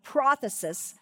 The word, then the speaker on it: prothesis: It would have the accent on the first syllable, prothesis.